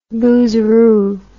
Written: boozeroo

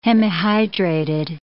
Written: hemihydrated

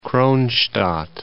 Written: Kronshtadt

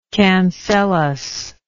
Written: cancellus

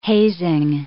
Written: hazing

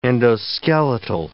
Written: endoskeletal

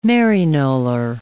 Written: Maryknoller

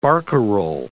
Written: barcarolle

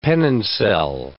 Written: penoncel

